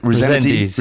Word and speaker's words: Rezendes: R- R- Resent- Rezendes, Rezendes yes.